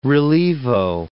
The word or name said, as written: relievo